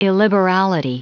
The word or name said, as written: illiberality